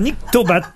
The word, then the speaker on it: nyctobate: Et pourquoi vous avez fait la liaison entre le N et le I ou ça s'écrit N-I? – Non, ça s'écrit N-Y-C-T-O-B-A-T-E. – Donc c'est lié à la nuit.